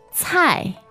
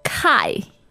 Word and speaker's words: 菜: Okay, that is fourth tone, and in pinyin it is written C-A-I. – Now, you might be tempted to pronounce this as kai. – All right, but that would be wrong. – No, the correct pronunciation for C in pinyin is a little bit like T-S, isn't it? – Yeah, 菜.